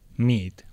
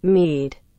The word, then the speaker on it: mead: Hem descobert què és, que Met és aiguamel, aiguamel, hidromiel, però anem a escoltar com es pronuncia. – Mead. – Mead, ho hem dit bé. S'ha sentit? – Mead.